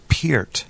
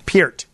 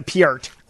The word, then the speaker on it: Peart: The correct pronunciation of his last name is Peart. – Peart. – Yeah. – Yeah. Well, it's America. You know, I mean, it's, you know, in Canada, maybe it's a Piart.